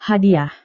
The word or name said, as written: hadiah